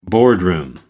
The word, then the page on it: boardroom: American English